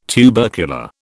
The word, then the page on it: tubercular: British English